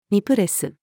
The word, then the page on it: ニプレス: female